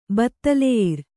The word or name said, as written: ಬತ್ತಲೆಯಿರ್